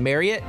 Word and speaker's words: Marriott: So how do you say it? Marriott? – Yeah, it rhymes with chariot.